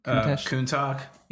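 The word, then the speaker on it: Countach: How do you pronounce that track? Kuntech? – Kuntak? Yeah. Kuntak. It's a Lambo.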